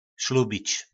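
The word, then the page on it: Slubic: nářečí Po našimu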